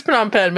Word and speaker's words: Phnom Penh: I'm pretty sure it's Phnom Penh, but yeah, that's the one I actually do know. – Phnom Penh.